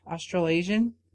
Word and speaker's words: Australasian: One side represented that of Australian Wait, oh, how do you say that? Australasian? – Yeah, Australasian.